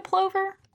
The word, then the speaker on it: plover: You ever seen a plover? – Let's look it up. – What's a plover? Oh, they're cute! – Aw, I've seen those. – Oh, and it rhymes with hover, so it's a plover.